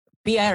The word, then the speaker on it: PIA: Un avión de Pia Airlines, así, así se dice.